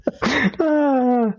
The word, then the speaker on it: Lapeer: L-A-P-E-E, two E's, R. I want to say like a Hebrew word, Lapeer. How do you guys pronounce it? – Lapeer. – Lapeer. Oh, just Lapeer. Yeah. Okay. – Lapeer. – Yeah, like it looks in American English.